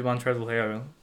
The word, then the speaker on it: Montrezl Harrell: Fadeaway with 30 seconds left where he basically turned to Montrezl Harrell.